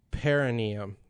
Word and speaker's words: perineum: Perineum. – Perineum. There we go. I cut a syllable out, but close enough.